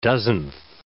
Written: dozenth

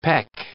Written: peck

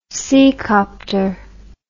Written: seacopter

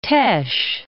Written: Teche, Bayou

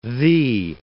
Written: thee

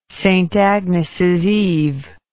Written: saintagnesseve

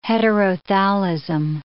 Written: heterothallism

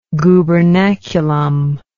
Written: gubernaculum

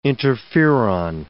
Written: interferon

